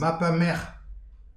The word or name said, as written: mab ha merch